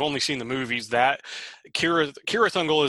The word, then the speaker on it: Cirith Ungol: Because it should be Cirith Ungol. The C should have a hard K sound.